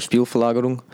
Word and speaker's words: Spielverlagerung: You write mostly for for Speer Val that is that's almost um, for Spielverlagerung.